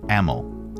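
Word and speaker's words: Amyl: Emil. All right. Sounds like Amy, but with a short ah.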